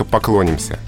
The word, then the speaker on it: поклонимся: Ударение в личных формах перешло на корень. От окончания оно уже оторвалось, и варианты «поклониться», «поклонимся» уже не допускаются.